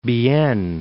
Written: Bienne

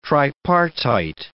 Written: tripartite

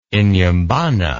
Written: Inhambane

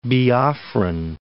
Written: Biafran

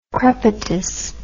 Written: crepitus

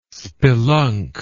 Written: spelunk

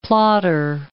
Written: plotter

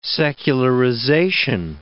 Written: secularization

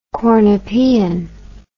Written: cornopean

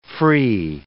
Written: free

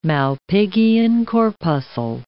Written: Malpighian corpuscle

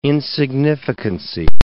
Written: insignificancy

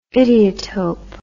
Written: idiotope

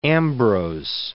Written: Ambrose

